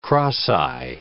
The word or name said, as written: cross-eye